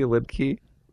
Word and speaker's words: Liedtke: It's my name, Randy Lipke. L-I-E-D-T-K-E.